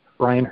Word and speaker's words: Brion: And my name is spelled Brion. It's a little different, but Brian Hurley.